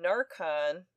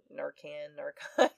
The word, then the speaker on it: Narcan: She also said she was never actually tested for drug overdose, nor was she given Narcan. I don't know if I'm saying that right, but it's N-A-R-C-A-N. Could be Narcan. Narcan sounds better.